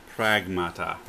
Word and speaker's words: Pragmata: And that one is Parmagata. Sorry if I'm butchering this one. It's Pargamata. Pargamata. – Let me see. – Yeah. – Sorry. Pragmata. – Pragmata, my bad.